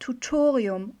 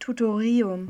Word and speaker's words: Tutorium: Was bringt denn so ein doofes Tutorium? – Ben, stopp, stopp, stopp. So raubst du den anderen Erstis noch die Motivation. Und übrigens, du hast die falsche Betonung. Es heißt Tutorium und nicht Tutorium.